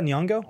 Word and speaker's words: Nyong'o: And then last but not least, Academy Award winner Lupita Nyong'o. How do you say it? – Lupita Nyong'o.